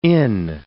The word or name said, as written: inn